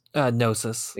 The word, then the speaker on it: Gnosis: Part of the culture here, there's an alien race called the Gnosis, or is it Gnosis or just Gnosis? – Gnosis. – Okay, so silent G, got it.